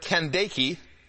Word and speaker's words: Candace: It says in verse 27, and he arose and went, and behold, a man of Ethiopia, a eunuch of great authority under Candace, is how it's pronounced,